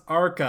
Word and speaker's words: Archi: So it is pronounced Archi. I have been educated, therefore you all have now been educated.